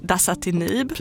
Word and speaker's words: dasatinib: D står då för dasatinib.